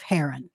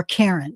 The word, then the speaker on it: Haran: Let's talk about the land of Haran, mm-hmm. – or Karen. It's pronounced a couple of different ways.